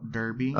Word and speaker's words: Derby: But the first thing, Frank Lampard and Jody Morris, of course, both Chelsea legends, on the brink of managing Derby County. What are your thoughts? – I think you're going to get slaughtered for your pronunciation of Derby County, first of all. – Derby.